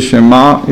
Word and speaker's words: Shema: and the ayin should be very qaliz, Baghdadi type, because if you pronounce it as an aleph, Shema means maybe or so what. So Shema means to accept and also to understand. Doesn't mean to hear. Shema Ayin has to be sounded different from Aleph.